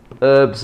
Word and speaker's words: herbs: It's the same with herbs and spices. – You're right. Herbs. – Yeah. – Yeah. Anyway, we eliminate the H.